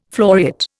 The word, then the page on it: floriate: Indian